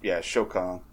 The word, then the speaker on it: Shokan: And so, again, forgive me, I'm going to say it wrong, and I know, but Goro's race, the Shokan or the Sukan? – Got it right. Shokan.